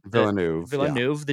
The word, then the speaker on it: Villeneuve: Villeneuve, the Dune guy.